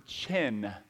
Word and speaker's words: chen: There's a Hebrew word, chen, C-H-E-N, we would spell it in English, and it literally means to stoop down or to condescend.